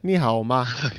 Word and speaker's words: 你好吗: 你好吗? – Yeah, good. – Did I have the tonality right? I can't remember. – You asked me. Yeah, very good. Very good pronunciation.